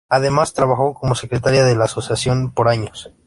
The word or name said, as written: secretaria